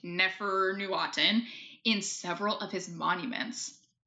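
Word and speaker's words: Neferneferuaten: I did really look up the pronunciation because I am very respectful of this stuff, but I still couldn't get it. Yeah, it's Neferne Furuaten,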